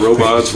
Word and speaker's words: Robhots: So I just went ahead and put BHO into the word robot, and that's where you get the spelling R-O-B-H-O-T-S. So I've always said the H is silent, but I've also said as long as you eat the gummies and like them, I don't care if you call it robots, Robhots, whatever it is, as long as you like Robhots.